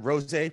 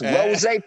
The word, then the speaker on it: RosA: Am I saying it right this time? RosA Productions? – Yes. – There RosA. – We go. – And just remember, it's RosA Productions.